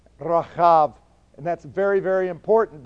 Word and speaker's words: Rahab: And so we have the same thing in Rahab's name.